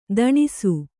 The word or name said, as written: ತೊಣಸಿ